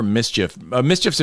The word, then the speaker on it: MSCHF: But the problem with this lawsuit, and they filed it either in New York or New Jersey, wherever Mischief's in Brooklyn. And mm-hmm. it's spelled funny, like M-S-C-H-F.